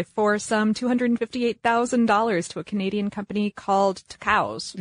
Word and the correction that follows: Tucows: Yeah, two cows. – Two cows? – Yep. Yeah, they have two cows, twice as many cows as someone who has one cow. So yeah, except it's spelled T-U-C-O-W-S.